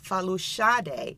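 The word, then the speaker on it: Folasade: And if you also listen carefully in that name, if you heard the pronunciation in her middle name, it's Falushade, and that is how her artist name came to be by taking out the Shade in Falushade.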